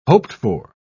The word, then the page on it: hoped-for: British English